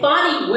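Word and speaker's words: Fani: No, but on name pronunciation, I do need to apologize to Fanny. It's Fonnie Willis, and if you listen to one of our previous podcasts, I did her an injustice.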